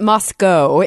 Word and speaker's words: Moscow: And then I flew, to the different, they pronounce it Moscow. It is spelled Moscow, but Russians.